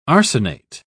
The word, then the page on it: arsenate: British English